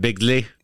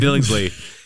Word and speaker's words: Billingsley: But it's weird that Ralphie, that Peter Billingsley, is that how you say it? Bigley? – No, it's just Billingsley. – Billingsley.